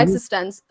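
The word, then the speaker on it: eXistenZ: Existence? Am I saying it right? Where they're like in a video game? Yeah, mm-hmm. Existence.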